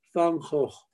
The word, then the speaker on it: van Gogh: En holandés se dice van Gogh, en español se dice van Gogh, pero en los holandeses pronuncian la G como J, van Gogh.